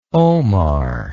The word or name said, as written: omar